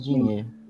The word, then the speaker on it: Digne: O Lucas Digne, que tem o Digne, talvez, não sei como é a pronúncia em francês. – É mais Digne.